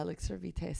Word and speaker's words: Elixir Vitae: It's really We're talking about the Elixir Vitae. Is that how you say it? – Yeah, Elixir Vitae Serum. Vitae. And it has an eye serum as well.